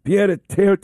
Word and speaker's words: pied-à-terre: That is this pied-à-terre tax.